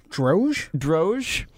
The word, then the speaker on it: Droge: Droge? – Yeah, I'd say it. – D-R-O-G-E.